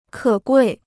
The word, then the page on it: 可贵: kě guì